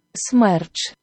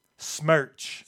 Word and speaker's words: Smerch: Smerch. – Smerch. – Smerch. – That is how you pronounce it. So S-Merch. – Yes, S-Merch.